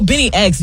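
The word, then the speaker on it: BNYX: Also, Benny X, Genesis, he works a lot with Drake as a collaborator. But don't spell it like B-E-N-N-Y. B-N-Y-X. Benny X, Genesis FM.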